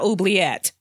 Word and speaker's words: oubliette: I'd never, you know, try to pronounce that in French. Oublier. Is that what you said? Oublier? – Yes. – I'd feel phony. So, excuse me. It's going to come out oubliette.